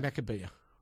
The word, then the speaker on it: Maccabiah: Maccabiah. – M-A-C-C-A-B-I. – A-H.